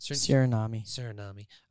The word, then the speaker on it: Suriname: Suriname.